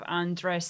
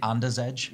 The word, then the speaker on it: Andrzej: because of Andrzej Sapkowski. – I used to always think Anders Edge, because it's spelt like Anders Edge.